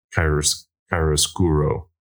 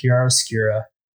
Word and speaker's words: chiaroscuro: chiaroscuro. – I think the word is pronounced chiaroscura.